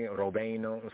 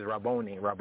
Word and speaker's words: Rabboni: Rabboni, Rovino, from the pronunciation Rabboni, Rovino. Kind of a Hebraically, there's a northern and southern pronunciation. People say the tomato, tomato, so to speak.